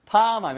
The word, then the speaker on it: palm: l이 묵음이에요. 이거 팔음이 아니에요. 그래서 팜 하면 바로, 예, 바로 손바닥을 이야기합니다.